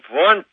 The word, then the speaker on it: Wundt: Uh, well, Wundt is uh, how it's pronounced, although it's spelled Wundt.